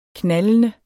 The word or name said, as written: knaldende